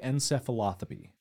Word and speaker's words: encephalopathy: But it's chronic traumatic encephalopathy. – Encephalopathy. Encephalopathy. encephalopathy. Encephalopathy. Yeah. Compound? – Chronic. – Chronic traumatic encephalopathy. Correct. CTE.